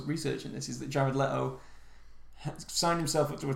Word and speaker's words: Leto: Apparently, Leto.